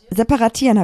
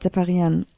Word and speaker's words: separieren: Separatieren habe ich gesagt, anstatt separieren. Ja.